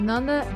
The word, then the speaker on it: Nanda: You can find me on Twitter at Nanda, that's NANDAPRATES, Nanda Prachis _.